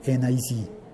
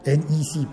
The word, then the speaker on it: NEC: Hafde dice, sí, el HD DVD de Toshiba, NEC y Microsoft, NEC, ¿se pronunciará NEC o se pronunciará NIC? NEC, perdón, NEC, Bueno, NEC. Eh, yo lo conocí como NEC.